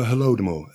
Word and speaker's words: Holodomor: The Holodomor, Holodomor.